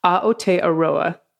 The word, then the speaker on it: Aotearoa: I chair a tiny volunteer community group set up to oppose seabed mining in, this is how it's pronounced. I'm learning it actively right now. Aotearoa, New Zealand.